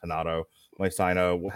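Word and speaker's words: Renato Moicano: Rafael Dos Anjos versus, I think it's actually pronounced Hanato Moicano.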